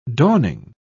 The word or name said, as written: dawning